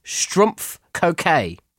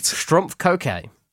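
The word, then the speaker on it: Schtroumpf Coquet: Number one, how do you pronounce Vanity Smurf in French? The answer is Strumpf Coquet. – Right. – Strumpf Coquet.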